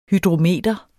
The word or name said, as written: hydrometer